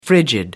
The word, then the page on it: frigid: American English